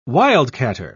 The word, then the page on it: wildcatter: British English